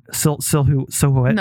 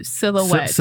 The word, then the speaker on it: silhouette: Silhouette. – No, don't pronounce the who. Silhouette. – Silhouette.